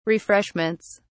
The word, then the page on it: refreshments: British English